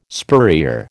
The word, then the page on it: spurrier: American English